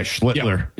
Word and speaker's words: Schlittler: Yeah, Schlittler.